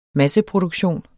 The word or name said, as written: masseproduktion